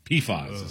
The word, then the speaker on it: PFAS: PFAS is how they pronounce it.